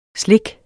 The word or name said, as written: SLIKKETS